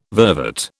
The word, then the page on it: vervet: British English